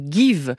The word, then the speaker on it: Geev: Elle s'appelle Give, ça s'écrit G-E-E-V.